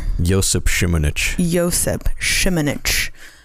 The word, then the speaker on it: Josip Šimunić: Josip Šimunić.